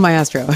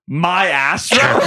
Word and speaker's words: Maestro: Because Heifetz said Maestro, right? He said ma, and then yes, like because of the E. She says Maestro. – She said Maestro. – Right, exactly. And Bradley Cooper goes, my Astro? – Like, very, sure. Very clearly emphasizing the first two syllables being my ass.